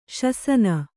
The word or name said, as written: ಶಾಸನ